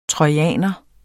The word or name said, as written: trojaner